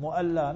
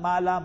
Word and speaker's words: Mu'alla: Mu'alla, not Mala, Mala. Mala, Mala is means little bit tapsi, you know, in Afrikaans. You know, so we must be pronounce words properly. If you go to the graveyard, it's called Mu'alla, the upper region of Makkah, where your hotels are, all your Zamzam, Hilton, all that.